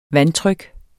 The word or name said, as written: vandtryk